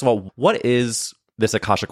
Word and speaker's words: Akashic: Can you tell me, first of all, you say that you offer Akashic Records.